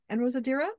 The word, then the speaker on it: En Rosadira: Am I pronouncing that again correctly? En Rosadira? – Yeah. Perfect.